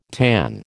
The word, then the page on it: tanh: American English